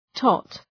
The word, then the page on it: tot: tɒt